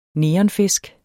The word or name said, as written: NEONFISKEN